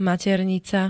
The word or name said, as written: maternica